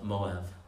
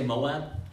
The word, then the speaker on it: Moab: In English, we say Moab. In your Bibles, it's spelled M-O-A-B. In Hebrew, we say Moab. Replace the B with a B. And the Hebrew letter bet, depending on how it's written, can be pronounced as a vet or as a bet. So or, like a B or a V.